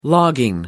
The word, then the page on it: logging: American English